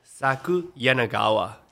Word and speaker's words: Saku Yanagawa: Saku Yanagawa.